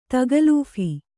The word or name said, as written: ತಗಲೂಫಿ